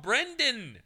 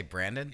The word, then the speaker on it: Brendan: Stop saying it wrong. Brendan. – Brendan. That's what did I say? Brandon? – You're saying Brandon.